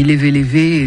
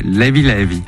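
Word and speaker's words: Leve Leve: Notre invité pour la sortie du volume 2 de ses compilations sur Sao Tomé et Principe, qui s'appelle, moi je dis Lévé Lévé, et ça ne se prononce pas comme ça. Comment ils disent là-bas ? – Lévi Lévi.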